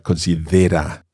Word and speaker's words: considera: Just watch the pronunciation and the stress of that word. Se considera. In English we say considered, but considera.